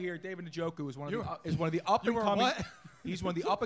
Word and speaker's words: Njoku: Okay, so his name is Njoku, not Njoku. So the top 5 tight end next year that was just selected couldn't be pronounced properly by the guy who said it. – He doesn't want that pick now. – Right. You ready for this? It's pronounced Njoku. How about that? – That is a good pick.